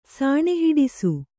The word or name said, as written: ಸಾಣೆಹಿಡಿಸು